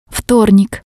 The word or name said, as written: vtornik